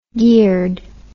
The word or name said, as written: geared